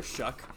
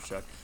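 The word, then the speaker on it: Shough: I believe it's Tyler Shuck. Brandon will pronounce me if I'm wrong, but it's spelled S-H-O-U-G-H. I'm pretty sure it's Tyler Shuck.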